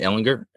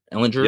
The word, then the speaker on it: Ehlinger: And if I'm Ehlinger, am I saying that right? Ehlinger, Ehlinger. Yep, you got it.